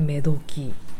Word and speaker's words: 女時: えっ、ー、と漢字は男の時と書いておどき女の時と書いてめどきと読むんですよ。